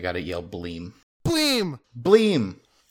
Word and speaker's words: Bleem!: And Bleem, Bleem is spelled B L E E M with an exclamation mark at the end always.